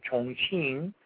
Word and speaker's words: Chongqing: He's an assembly line worker in a factory in Chongqing, China. That's how you actually pronounce it.